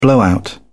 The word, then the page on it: blowout: British English